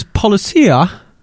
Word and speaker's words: Policia: Yeah, this is Policia. Are we pronouncing it like that? – Yeah. You just did.